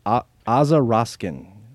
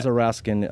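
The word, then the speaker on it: Aza Raskin: The way I remember Aza Roskin's name, so you guys remember it, is it rhymes with Baza Poskin.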